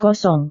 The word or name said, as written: kosong